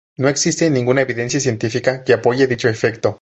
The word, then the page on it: evidencia: spanisch